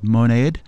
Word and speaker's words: Monade: We're going to play some music from a band affiliated with Stereolab called Monade, or it's M-O-N-A-D-E, and as they are French-speaking folk, perhaps that's pronounced in another way. Monade is the way I've said it in my head.